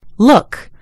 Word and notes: look: British English

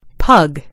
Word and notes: pug: British English

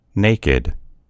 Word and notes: naked: American English

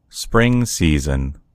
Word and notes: spring: American English

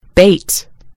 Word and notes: bait: British English

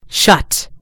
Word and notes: shut: British English

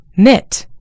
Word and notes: knit: American English

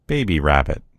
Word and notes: rabbit baby: American English

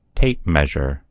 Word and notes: tape measure: American English

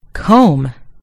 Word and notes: comb: American English

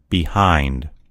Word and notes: behind: American English

